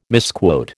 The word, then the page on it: misquote: American English